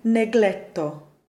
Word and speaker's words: negletto: And the spelling really needs to be G-L-I. There are some specific cases where G-L appears, and we do pronounce it as a phonetic G-L, like all the variations of the word negletto.